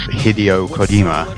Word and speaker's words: Hideo Kojima: His name, I think, if I pronounce it correctly, is Hideo Kojima, or something.